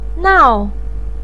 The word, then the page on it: nao4: pinyin